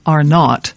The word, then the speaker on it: Arnaud: It's spelt Arnaud, but it's actually pronounced Arnaud.